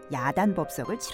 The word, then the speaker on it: 야단법석: 그런데 야단 법석의 척을 된소리로 발음하다 보니 표기도 된소리로 하는 경우가 많은데 이는 틀린 표기입니다. 소란스럽게 떠드는 모양이라는 뜻의 법석은 발음을 된소리로 하지만 표기는 호자음을 쓴 석으로 합니다.